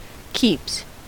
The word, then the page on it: keeps: American English